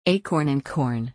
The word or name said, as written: acorn and corn